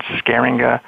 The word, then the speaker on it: Scheeringa: If you just like do an internet search on Scheeringa Lab, my last name is spelled S-C-H-E-E-R-I-N-G-A, Scheeringa Lab.